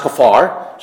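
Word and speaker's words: kaphar: And that's pronounced Kafar.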